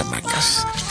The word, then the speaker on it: Hamaques: Pero como es en catalán, se escribe con Q-U-E-S, ¿no? Se escribe Hamacas, pero se pronuncia Hamacas.